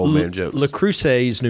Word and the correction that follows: Las Cruces: New Mexico. I'm not sure if I'm saying that right, but you're not. – I mean, Las Cruces.